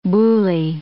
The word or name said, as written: boule